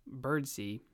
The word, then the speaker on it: Birdseye: I have seen some hubbub about the name pronunciation and how it is actually McPherson, and his middle name is actually pronounced Birdsee, where it's spelled Birdseye, but I mean, come on, Birdseye, what an awesome middle name.